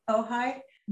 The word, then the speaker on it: Ojai: And finally, my husband and I were up here in Ojai, which is this beautiful, do you know Ojai? – Yeah, I didn't say it on the introduction because I couldn't know how to pronounce it. – Oh yeah, it's a tricky one, O-J-A-I, it's Chumash Indian, Ojai.